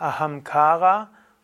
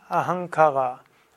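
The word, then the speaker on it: Ahamkara (अहंकार): Also es ist Ahamkara und nicht Ahankara. Also das zweitletzte A ist lang und damit ist auch eine Betonung drauf. Ahamkara.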